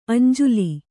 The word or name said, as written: ಅಂಜುಲಿ